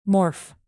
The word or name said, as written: morph